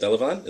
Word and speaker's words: Delavan: Delavan? Is that how you pronounce it? Yeah.